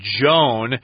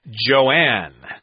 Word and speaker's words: Joan: I should say J-O-A-N is pronounced Joan most of the time, but I have met people here in Taiwan who insist on pronouncing it as Joanne. I don't know why, but that's pretty rare. Most of the times it's Joan. My aunt's name was Joan, so I would know. – Joanne is spelled differently, J-O-A-N-N-E, or with an E at the end, J-O-A-N-N-E, J-O-A-N-N.